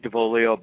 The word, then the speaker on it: D'Avolio: You're pretty close. It's Mike DeVuglio, but don't worry. A lot of people mess it up.